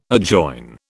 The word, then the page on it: adjoin: British English